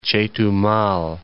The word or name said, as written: Chetumal